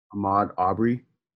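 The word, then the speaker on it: Ahmaud Arbery: Ahmed Arbery? – Ahmad. Did I say that right? Ahmad Arbery. – Ahmad Arbery.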